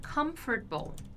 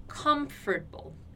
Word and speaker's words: comfortable: Outra palavra que eu posso dar de exemplo é comfortable. Comfortable. É a mesma coisa. Eu tenho um M no meio da palavra antes de uma consoante. Então eu tenho que pronunciar esse M fechando ali os meus lábios.